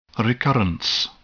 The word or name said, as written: recurrence